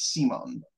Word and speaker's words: Simone: I should say his name is spelled like Simon, but they're very clear to pronounce it. It's Simone.